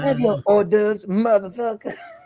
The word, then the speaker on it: hors d'oeuvres: You mean hors d'oeuvres? – Oh, is that how you say it? I always thought it was. Because it's spelled H-O-R-D. – Hors d'oeuvres. – Hortivores, okay. – It's hors d'oeuvres. – Hors d'oeuvres. – You don't pronounce the H.